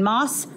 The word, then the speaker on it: MAS: But first of all, you're here for the Arnold to compete in Moss Wrestling.